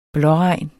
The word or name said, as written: blåregn